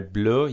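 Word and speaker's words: bleue: فرم فمینن در میان درست هرچند که برای بلو یک ا در انتهای لغت اضافه میشه ولی تلفظ تغییر نمیده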